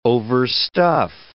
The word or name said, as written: overstuff